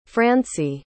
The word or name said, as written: francie